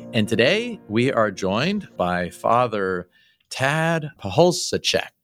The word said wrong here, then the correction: Pacholczyk: Is that right, Father? – It's close. It's Pahulcik. – Pahulcik. – Yes. Okay. Chick like a chicken at the end.